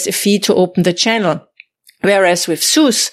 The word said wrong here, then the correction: Zeus: uh, Zeus, how, how do you say SUS? Zeus. – The wallet. Yeah, I say Zeus, yeah.